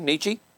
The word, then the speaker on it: Nietzsche: Okay, Nietzsche.